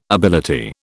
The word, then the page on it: ability: British English